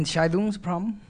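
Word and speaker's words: Entscheidungsproblem: The Entscheidung problem? – Yes, the decision problem. – Entscheidung problem? – Thank you.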